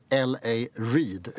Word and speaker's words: L.A. Reid: Av en middag som hon är bjuden på och en skivbolagsmagnat som heter L.A. Reid, uttalas det va? – Ja.